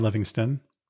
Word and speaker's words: Livingstone: In 1869, the New York Herald sent Stanley to find British explorer and missionary David Livingstone, who hadn't been heard from in 6 years. I've also heard his name pronounced Livingston more commonly, so I'll go with that.